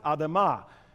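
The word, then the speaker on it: Adama (אדמה): From the dust of the ground, the Hebrew word for ground is Adama. It sort of rhymes with Adam.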